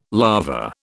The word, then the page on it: larva: British English